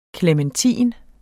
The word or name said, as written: klementin